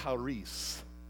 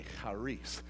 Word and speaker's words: charis: And make sure you put the cha on it. You know what I'm saying? Because it's a Hebrew term. Charis, right? And it's spelled C-H-A-R-I-S. Now, most people say it's charis, but it's not. It's pronounced charis.